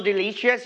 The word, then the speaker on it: delicious: All right, so delicious, you need to stress on the second syllable, delicious.